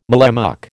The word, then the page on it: mallemuck: American English